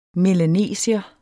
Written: melanesier

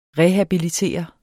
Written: REHABILITERE

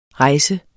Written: rejse

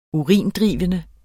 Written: urindrivende

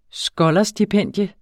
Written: skolarstipendium

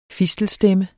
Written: FISTELSTEMME

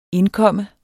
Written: indkomme

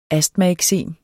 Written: astmaeksem

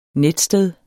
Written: netsted

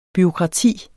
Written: bureaukrati

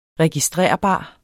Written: registrerbar

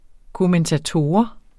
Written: KOMMENTATORER

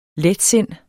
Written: letsind

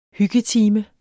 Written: hyggetime

